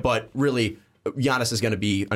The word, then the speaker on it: Giannis: I believe you said the name wrong. It's Giannis. – That hard G. – Bunk fans are really going to be mad at you. – Giannis Antetokounmpo.